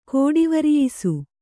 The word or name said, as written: ಕೋಡಿವರಿಯಿಸು